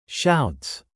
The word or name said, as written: shouts